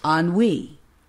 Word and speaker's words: ennui: ennui, ennui. – 지루함, 권태. 발음이 ennui, 그러니까 어때요? 네, 아무리 봐도 발음이 이상하죠? 이거는 왜냐하면 프랑스에서 와서 그래요.